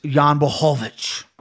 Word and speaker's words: Jan Blachowicz: Let me correct you. It's Jan Blachowicz. You got to say it like that. – Jan Blachowicz.